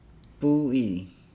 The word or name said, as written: buu ili